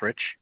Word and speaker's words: Fritch: The last name is Fritch.